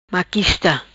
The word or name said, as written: Maquista